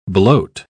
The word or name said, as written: bloat